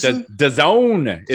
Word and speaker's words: DAZN: No, because NFL has days and days. – DAZN is apparently how it's pronounced. – D-A-Z-N.